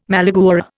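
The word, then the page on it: malagueña: Indian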